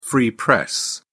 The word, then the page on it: free press: British English